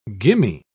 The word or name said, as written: gimme